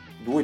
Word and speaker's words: Dooo: Eh, e questo diciamo mi è piaciuta per, per, per molto tempo, l'ho tenuta, fino a quando due giorni fa ho scoperto Du, che si scrive D-O-O-O, tutto maiuscolo.